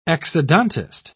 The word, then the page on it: exodontist: British English